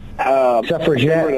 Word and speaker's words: suffragette: A woman's suffragette. – An activist. Yeah. How do you say it? – Suffragette. – Suffragette. Like a jet plane.